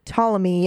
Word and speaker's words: Ptolemy: Ptolemy, yeah. – I'm sure the P is silent.